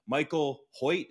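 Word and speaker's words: Michael Hoecht: Michael Hoyt, is that how you say his name, Hodge? – That's correct.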